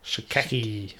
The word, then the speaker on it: Schache: It's spelled S-C-H-A-C-H-E. – Yeah. – Shackey.